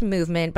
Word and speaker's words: MVMT: And so when MVMT, pronounced movement, but I just have to say it's MVMT.